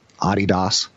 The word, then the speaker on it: Adidas: So Nike was around when I was a kid, as was Adidas, which I've recently learned you're to correctly pronounce it. You're supposed to say Adidas, Adidas.